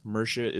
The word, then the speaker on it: Mierce: Mirsha is spelled M-I-E-R-C-E.